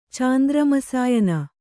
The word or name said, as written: ಚಾಂದ್ರಮಸಾಯನ